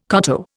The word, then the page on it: koto: Indian